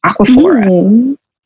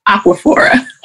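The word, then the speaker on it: Aquaphor: And, you know, because I'm working in a rural area and I'm constantly buying like Avena and Aquafina. And did I say that right? Aquafina? Aquaphora. – Is it Aquaphora? – Aquaphora. Aquafina is a water company.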